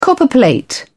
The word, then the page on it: copperplate: British English